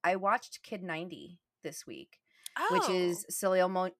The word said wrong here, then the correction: Soleil: I don't think you use the last L, though. – Soleil. – Like, thank you. Soleil. Yes, yeah.